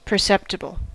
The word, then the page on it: perceptible: American English; [pə(ɹ).ˈsɛp.tə.bəl]